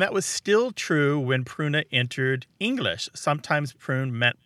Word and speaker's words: pruna: But and that word was pruna. Martha, how's my pronunciation on Latin? It's P-R-U-N-A.